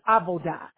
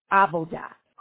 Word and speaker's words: avodah: The first word is avodah. It is spelled A, V as in Victoria, O-D-A-H, avodah.